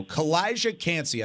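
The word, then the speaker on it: Calijah Kancey: Kalijah Kancey. I said his name wrong. Butchered it in the first segment. That's okay. Got it right this time.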